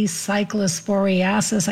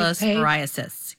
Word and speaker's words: cyclosporiasis: If you are worried, there is a CDC cyclosporiasis. I didn't pronounce that right. – Outbreak cyclosporiasis. Yes.